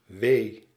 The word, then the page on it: pain: /peɪn/